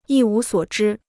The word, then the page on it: 一无所知: yī wú suǒ zhī